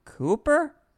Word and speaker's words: Kuiper: Speaking of saying the thing, Oakland A's announcer, broadcaster, Glenn, how would you say this? K-U-I-P-E-R. Cooper? – Sure.